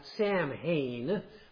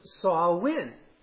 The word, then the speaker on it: Samhain: So from October 31st through November 2nd, they observed a festival called, well, it looks like Samhain, but it actually is pronounced Samhain,